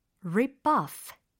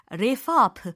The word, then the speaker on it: rip off: 특히 이 발음이 마치 refop 이렇게 반대로 잘못 발음하시는 분들이 좀 계신 것 같더라고요. rip off라고 하셔야지 rip up 이렇게 하시면 안 된다라는 거 짚어드리고 싶었어요. p와 f 사운드를 잘 구분을 하시기를 바랍니다.